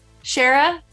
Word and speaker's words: Shara: Thank you, Shahar, for coming on this episode of Share Your Hot. – You said my name wrong again. Shara. Shara. Like Sarah, but Shara. – Yeah. Shara, thank you for coming and sharing on this episode of Share Your Hotness.